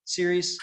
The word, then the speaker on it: Ceres: And I should say, when you say Ceres, that's a town and it's not spelled like World Series. It's Ceres, Ceres? – Correct. Correct. Okay.